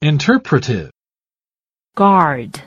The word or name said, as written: interpretive